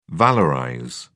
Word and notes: valorize: British English

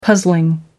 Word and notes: puzzling: American English

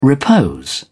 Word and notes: repose: British English

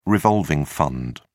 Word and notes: revolving fund: British English

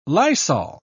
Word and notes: lysol: British English

